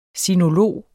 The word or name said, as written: sinolog